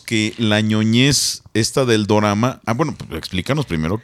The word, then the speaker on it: dorama: Bueno, dorama viene de drama, pero en, en Japón se pronuncia dorama, porque tienen esta...